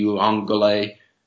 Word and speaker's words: Uiagalelei: And DJ Uiagalelei actually said it right.